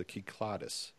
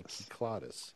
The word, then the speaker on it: Cyclades: But when I was over there a long time ago, it was pointed out it was the Kyklades. And that's the way I've been pronouncing it ever since. Now, I don't know if that's correct or not, but that's what I was told, Kyklades.